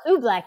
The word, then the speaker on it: oobleck: I'm probably going to say it wrong, but making oobleck. Is that how you say it? – Oh, oobleck.